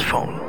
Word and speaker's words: Phaune: It's spelled P-H-A-U-N-E, but it's pronounced phone.